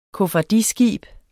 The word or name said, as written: koffardiskib